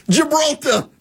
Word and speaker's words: Gibraltar: The Hormuz Strait, Malacca, Japan, Gilbertar. I always say that wrong. Gilbert. You guys know. Gibraltar.